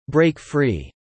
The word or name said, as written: break free